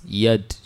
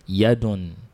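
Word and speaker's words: yad (يد): So the Arabic word for the hand is yad. Say it. – Yad. – Yeah, if you want to complete the pronunciation, we say yadun with dumatan. Yadun. That's a hand.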